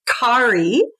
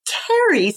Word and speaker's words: Kerry: I'm Scottish and my our family name is way back when is Carrie with a C-A-R-R-I-E. But actually my mother, because she's Scottish, said, I did not want to call you Carrie. I wanted your name to be Carrie. So she spelled it K-E-R-R-Y. So the pronunciation thing is quite cute.